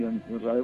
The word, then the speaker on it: John Rabe: Sí, bueno, John Rabe, que se pronuncia así, John Rabe,